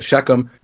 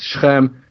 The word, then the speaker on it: Shechem: Now, some people pronounce that Shechem. It's Shechem.